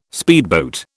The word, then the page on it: speedboat: British English